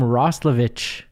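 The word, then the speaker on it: Roslovic: But he pronounced his name Roslovich.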